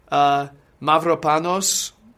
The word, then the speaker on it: Mavropanos: They bought Mavropanos, Greek defender, I believe he's a defender, yeah.